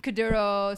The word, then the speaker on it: Kuduro: So the bigger genres of like Afrobeats and Afro House, but even the smaller genres of Gom or as it's actually pronounced, Kuduro, Sugubu, Kwaito, all these really dope musics coming out of Ghana, Nigeria, Angola, South Africa.